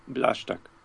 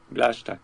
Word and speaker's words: Blaszczak: It's Pawel Blaszczak. – Blaszczak. – Blaszczak, yes.